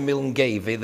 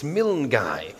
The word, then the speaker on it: Milngavie: Everybody knows, of course, that if you go slightly north of Glasgow, the village of Milngavie is actually Milngai. You all know that, don't you? So next time you're up there, just remember, as you're asking the way to Milngavie, they will laugh at you. It's Milngai. If you go to a little place on the Black Isle, it's spelt A-V-O-C-H, and that's just pronounced Och.